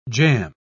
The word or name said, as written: jam